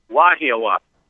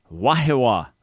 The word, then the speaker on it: Wahiawa: All right, how do I pronounce it in Hawaii? What's the right pronunciation there? – Wahiawa. – Wahiawa. Okay. Yep.